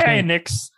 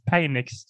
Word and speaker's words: Penix: Det heter typ Penix! – Eh, eh, kan du mm. inte det Alexander? Det heter Penix.